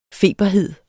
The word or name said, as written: feberhed